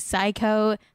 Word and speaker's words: Syco: Psycho, that's so funny. I think Psycho is the name of Simon Cowell's company. Psycho, but it's spelled S-Y-C-O.